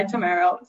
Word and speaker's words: Tammaro: When we went to Italy, it's funny. They told us that's actually how it's pronounced, but here we go by Tammaro.